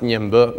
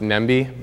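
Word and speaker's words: Ñemby: It's pronounced Ñembo. It looks like it's pronounced Nembi, but you say Ñembo, and we had fun.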